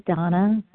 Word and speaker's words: Dana: This is Donna B. My first name is spelled D-A-N-A in Denver, Colorado.